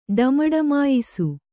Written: ಡಮಡಮಾಯಿಸು

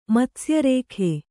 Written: ಮತ್ಸ್ಯರೇಖೆ

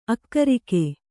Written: ಆಕರಿಕೆ

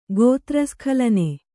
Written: ಗೋತ್ರಸ್ಖಲನೆ

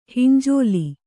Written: ಹಿಂಜೋಲಿ

